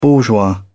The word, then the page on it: bourgeois: British English